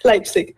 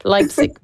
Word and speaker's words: Leipzig: Et donc, on le disait, tu as étudié l'illustration en Allemagne à Leipzig. Je sais pas si je le prononce sûrement pas bien là, comme une Allemande, là, mais comment tu dis? – Leipzig. – Leipzig, voilà. Quel avantage ou en quoi se distingue l'Allemagne dans le domaine de l'illustration? – C'est une bonne question.